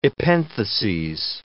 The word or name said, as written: epentheses